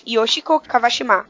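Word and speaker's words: Yoshiko Kawashima (川島芳子): É, Kawashima Yoshiko. Yoshiko. – Eu acho que é e... Kawashima que fala. Eu acho. Kawashima. Então, eu peguei a pronúncia, tipo, Yosh... Yoshiko Kawashima. Uhum. – Isso. pronúncia assim.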